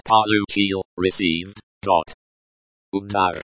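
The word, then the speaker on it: Udar (удар): The letter, u, in. Udar. Pronounced udar, is pronounced like the, u, in, moon. The letter, a, in. Udar. Pronounced udar, is pronounced like the, a, in, father.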